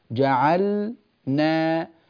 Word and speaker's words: جعلنا: كما تفعلين في ال تقولين جعل جعل جعل، وبعد أن تضبطي هذا المقطع، مقطع جعل، تضيفي بعده نا جعل نا، افصليها هكذا كما أفعل في التدريب، جعل نا جعل نا جعلنا، وتقربينها حتى تصير جعلنا بهذه الطريقة.